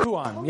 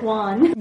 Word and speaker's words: Huan: Huan, yes. Yeah, Huan. – It's Spanish, like Juan. – No, it's not Juan. Two syllables. There's an accent over the Udomic. Two syllables, yeah, yeah.